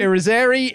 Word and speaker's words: Irizarry: I assumed it rhymes with scary. So yeah, Irizarry? – Irizarry is scary.